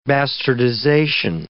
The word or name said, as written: bastardization